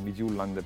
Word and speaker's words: Midtjylland: eh, perché arriverà anche Isaacsen nei prossimi giorni, arriverà probabilmente lunedì perché il, il Midtjylland, un Midtjylland per dirlo alla danese, era impegnato...